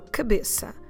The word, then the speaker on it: cabeça: So cabeça, C A B E C de cedilha A.